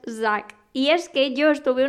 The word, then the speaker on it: Zac: Y él me dijo, pues te voy a enseñar cómo se dice su nombre por si algún día le conoces, que al menos sepas pronunciarlo. Y es Zack.